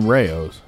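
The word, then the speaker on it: Rao's: Maybe I'm saying it wrong, but I'm thinking it's Rouse. – You mean Rayo's.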